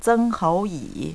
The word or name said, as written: zenghouyi